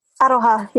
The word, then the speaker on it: Aroha: Aroha, yeah.